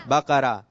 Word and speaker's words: بقرہ: آپ کو بھی مدنی تحفہ ملے گا, اور آپ نے صحیح تلفظ بتایا کہ سورت کا نام کیا ہے؟ بقرہ. دونوں آ جائیے.